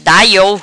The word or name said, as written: Da Iou